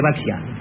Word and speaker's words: Baxian: Los Pa Xian se escribe, pero se dice Baxian. Los Baxian.